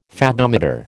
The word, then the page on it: Fathometer: American English